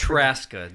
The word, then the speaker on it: Traska: Because I always Traska. Just the Z is silent. – Traska. So it's like Alaska, it's Traska.